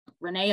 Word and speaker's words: Renae: Renee is spelled R-E-N-A-E.